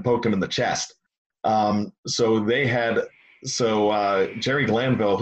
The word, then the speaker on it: Jerry Glanville: You know why I'm calling him Gary Granville and not Jerry Glanville? – That was how the Three River Stadium PA announcer pronounced his name back when he first started in 87, 86.